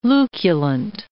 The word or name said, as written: luculent